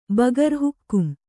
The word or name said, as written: ಬಗರ್ ಹುಕ್ಕುಂ